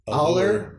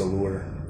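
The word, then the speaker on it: Aullar: Yeah, it's A-U-L-L-A-R. Aller? I was going to say Aller. – Maybe, but. – Or Aller. – It may look like that, but I think it's pronounced Allure.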